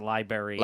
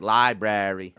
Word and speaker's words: library: Oh, since we're talking about, languages and stuff, does anyone in your family pronounce it Liberry? – No. Ooh. I will say that. – Because in the promo for our show, I pronounce it Liberry. – Liberry? – And I get trashed every fucking week. – But the New Orleans accent would force you to say it like Library.